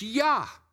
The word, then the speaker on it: Jah: Praise ye Yah. There, J A H, that's fine. German has J's as a Y sound. It's, it's Yah. Praise Yah.